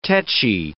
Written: tetchy

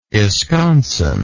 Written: esconson

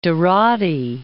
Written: Dorati

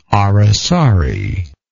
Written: aracari

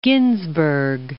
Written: Ginsburg

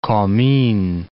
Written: Comines

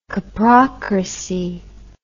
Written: coprocrat

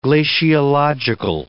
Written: glaciological

